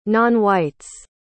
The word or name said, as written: nonwhites